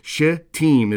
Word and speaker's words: Shittim: You have to be very careful. There's a little swear word hidden in that location. So Shittim is the way you want to pronounce that.